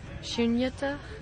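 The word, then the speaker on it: śūnyatā: Shunyata. Jnanam. So this means understanding emptiness. There's a little dot under this H. – And a short Sorry. It's a long U. Yes, thank you. Shunyata.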